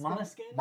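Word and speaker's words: Måneskin: Maneskin, that's how I've heard it pronounced.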